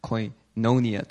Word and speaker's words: koinonia: Koinonia. – Actually, it should be koinonia. The accent is on the O, the second O.